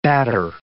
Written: batter

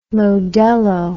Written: modello

